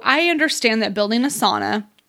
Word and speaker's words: sauna: Or I've heard the correct way to say sauna is not sauna, but sauna.